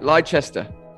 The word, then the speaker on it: Leicester: So you were born in Leicester or Leicester, as we think it's called here in the U.S. Leicester. What is your story? How do you go from like a pronunciation there? – That's right. Leicester.